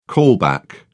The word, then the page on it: callback: British English